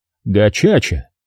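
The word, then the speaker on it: gacaca: Кажется, это называется словом «гакака». Терри подтвердил, что такой вариант рассматривался. «Но, простите, что поправляю вас, Джерри, на их языке, киньяруанда, это произносится не «кака», а «чача», «гачача».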